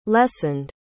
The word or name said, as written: lessened